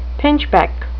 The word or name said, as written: pinchbeck